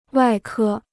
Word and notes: 外科: wài kē

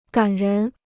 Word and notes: 感人: gǎn rén

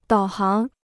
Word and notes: 导航: dǎo háng

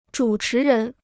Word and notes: 主持人: zhǔ chí rén

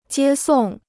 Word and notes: 接送: jiē sòng

